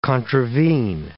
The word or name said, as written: contravene